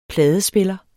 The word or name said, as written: pladespiller